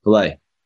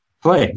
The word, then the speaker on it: Fallet: F A L L E T. How do you say it? Filet? Filet. Filet.